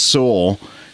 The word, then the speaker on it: Sowell: That's well, one of my favorite things, I guess it was Thomas Sowell, and I'm probably saying that wrong. – Nope, you're saying it right.